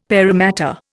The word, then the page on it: paramatta: Indian